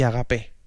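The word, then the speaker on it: ágape: Eros, filia y agape. Yo pongo el acento en la E, otros en la primera A. Bueno, se puede hacer de las dos maneras, ¿no?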